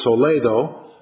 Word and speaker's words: Toledo: And I was in the city of Toledo, they would pronounce it Toledo, in Spain, beautiful old city, and I was there on Corpus Christi.